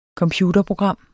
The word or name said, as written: computerprogram